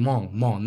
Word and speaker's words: Hmong: But Hmong is like Hmong, like that's how it's pronounced. Hmong, no Mongolian, but Hmong.